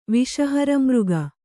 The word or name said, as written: ವಿಷಹರಮೃಗ